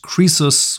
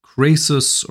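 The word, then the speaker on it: krisus (כריתות): That being the case, the truth is that, grammatically speaking, it shouldn't be pronounced krisus with a shva, but rather either krasus or karesus, meaning severances. But anyways, if you pronounce it karesus instead of krisus, in circles that I move in, you're going to be looked at as a very strange person. So therefore, it just goes by krisus, whether you like it or not, whether the grammar makes sense or it doesn't, okay?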